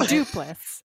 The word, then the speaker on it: Duplass: Duplass? – Yeah, and then Duplass.